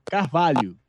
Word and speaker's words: Carvalho: Carvalho.